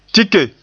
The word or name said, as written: tike